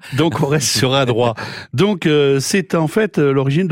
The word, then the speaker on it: Adroit: Oui, alors je suis étonné, parce que vous qui prononcez surtout les lettres finales, Franck, sans faire de politique, vous avez bien dit Adroit et pas à droite.